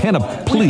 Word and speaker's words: panoply: Panoply is the actual pronunciation. Not many people know it, and the phonetic description in the script is certainly incorrect.